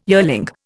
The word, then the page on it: yearling: Indian